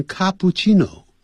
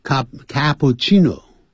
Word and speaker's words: cappuccino: 也有念成 cappuccino, 啊。这是意大利话，所以两个 C, 要念成气, 啊，这样气, 气的音。 cappuccino, 啊 ,cappuccino, 或者有念成 cappuccino。